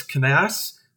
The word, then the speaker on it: Cannasse: Our next guest is Nicholas Canass.